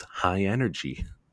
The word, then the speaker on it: high NRG: It's high dash and then the letters NRG. – NRG. – Pronounced high energy.